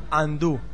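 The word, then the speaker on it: undo: En inglés deshacer se pronuncia andú.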